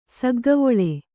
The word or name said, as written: ಸಗ್ಗವೊಳೆ